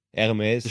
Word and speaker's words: Hermes: Hermes. Stille, stille H, so wie bei Hansa. Sagst du ja nicht Hansa, sagst du ja Ansa. Ansa.